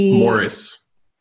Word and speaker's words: Maurice: But there was this one guy who I feel like probably wants to talk about, named Maurice Gross. – Ew. – Why do you like this? Maurice Gross. – Morris. – Yeah, Morris. – That guy. – Morris. – Morris. – They pronounce it.